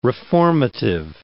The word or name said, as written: reformative